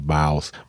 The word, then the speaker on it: Boss: aber so, aber abgeleitet eigentlich von wie südamerikanisch, also in, in, äh, aus, aus Down South, so Atlanta und so, die Jungs Boss aussprechen. Die sagen so Baus.